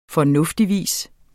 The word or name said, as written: fornuftigvis